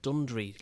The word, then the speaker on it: Dundry: Dundary. I met a person last night who tells me it's pronounced Dundry locally.